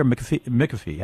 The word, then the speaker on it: McAfee: Is it McAfee or McAfee? How do you pronounce it? – It's McAfee.